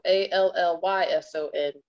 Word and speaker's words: Allyson: And my name is spelled with two L's and a Y. So Allyson Royal.